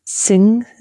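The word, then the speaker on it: Singha: The main Thai beer is Sing. It's spelled like Singa, which means lion, but it didn't come up in this category.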